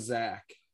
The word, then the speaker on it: Zach: but so my name is Zach and I spell it Z-A-C-H.